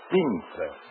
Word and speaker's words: Pinza: Ezio. E-Z-I-O. Ezio. Pinza. P as in Peter. I-N-Z-A. Ezio Pinza.